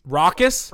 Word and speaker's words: raucous: That was as, I can't pronounce this word. Help me, guys. R-A-U-C-O-U-S. – Raucous? – Raucous as I've ever heard.